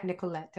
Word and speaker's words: Nicolette: Is that how you pronounce your name, Nicolette? – Yeah, Nicolette. I love how you say it.